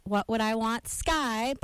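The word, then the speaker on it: What Would I Want? Sky: In my mind, in my mind, Are listening to WONY 90.9 Red Dragon Radio, and that was What Would I Want Sky by Animal Collective. What would I want ? Sky. Is the correct way to say it.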